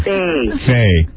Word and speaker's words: Thaye: I spell it T-H-A-Y-E. Okay. – Faye.